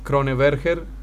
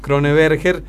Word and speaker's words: Kroneberger: que es Kroneberger. Lo estoy pronunciando como se escribe, así que está bien. Kroneberger con el 30% de los votos,